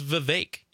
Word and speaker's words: Vivek: I've heard it several times now, but I believe it's Vivek. It apparently rhymes with lake or fake.